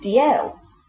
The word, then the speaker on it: Dalziel: Similarly, the Scottish surname spelled D-A-L-Z-I-E-L, pronounced D-L. So the Z isn't a Z. It's holding the space where...